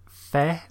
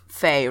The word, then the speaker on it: Fe: I think it's Fe, right? It sounds like it should be Fe just because of the premise of the game and the... It looks like a fae.